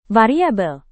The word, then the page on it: variabel: Indonesian